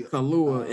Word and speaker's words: Kaluuya: Kaluuya and give Lakeith.